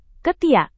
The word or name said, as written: ketiak